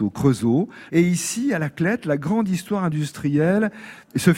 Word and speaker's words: La Clayette: Alors ça s'écrit La Clayette, mais on prononce depuis toujours ici La Clète.